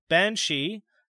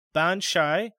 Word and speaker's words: Banshee: The name of this dreaded attendant is variously pronounced as Banshee, Banshai, and Banshee, being translated by different scholars.